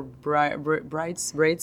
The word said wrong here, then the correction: breeds: of dog. How do you say that? Like different species of dogs. Like braids? – Yeah. Breeds. – That's the word I was looking for. – Breads? – Yeah.